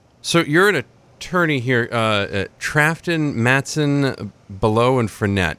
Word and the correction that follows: Bellow: And I would be remiss in not correcting your pronunciation of my good friend, Jamie Bellow.